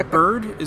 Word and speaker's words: Byrd: Because Byrd, is that how you pronounce it? B-Y-R-D? – I think so. Yeah, that sounds right.